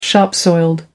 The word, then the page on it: shop soiled: American English